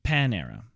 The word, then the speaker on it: Panera: It's actually pronounced Panera. – Panera. – Panera. – Panera. – Yeah, like it's Pan-era bread, like the god Pan.